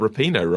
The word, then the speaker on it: Rapinoe: Not sure I got Rapinoe right. – Yes, you did. – Oh, fantastic. – You did, because it's not Rapinoe, it's Rapinoe.